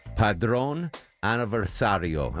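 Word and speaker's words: Padrón Aniversario: Padron Aniversario. Am I pronouncing that okay, Deborah? How's my... – Absolutely.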